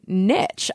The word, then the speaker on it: niche: I say niche, too. I don't say niche, but that's a very American thing. Some Americans say niche, but I think they sound pedantic. – Niche is American, niche is British.